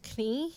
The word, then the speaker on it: knee: No, see, you're in like knee, knee. – Knee. – Right. So the K is silent, but it needs the K.